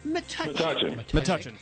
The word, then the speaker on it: Metuchen: Today's contestant for the game in Metuchen. Is that how you say it? Metuchen? – Metuchen. – Oh, I'm sorry.